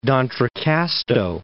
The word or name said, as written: D'Entrecasteaux Islands